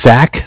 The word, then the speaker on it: Zach: my name is Zach, spelled Z-A-C-H. – Okay, wait a second. Z-A-C-H? – Yes, and I was just following up.